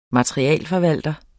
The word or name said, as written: materialeforvalter